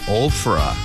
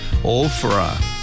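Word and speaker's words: Ophrah: 자, 먼저 오브라를 영어로 이렇게 발음합니다. 오프라. 오프라. PH가 있어요. F 소리가 납니다.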